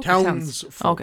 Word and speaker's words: Townsfolk: Yes, Townfolk Tussle. Did I say it wrong? Is it Townfolk? – Townsfolk okay.